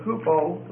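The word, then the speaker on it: hupo (ὑπό): The word hupo means under. Not hooper, which means over, but hupo, which means under.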